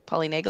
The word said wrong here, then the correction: polyvagal: Polyvagal polyvagal theory. Polyvagal theory.